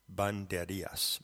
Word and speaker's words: Banderillas: To begin, I want to make a correction in my pronunciation. In the previous audio commentary that was sent out to go along with Crew Notes 21, I'm referring to the title of the Picasso image, which is Dance with Banderillas, Banderillas, B-A-N-D-E-R-I-L-L-A-S. So that is the correct pronunciation, and that's the way that you should say it in your mind when you're looking at that magnificent and enigmatic image.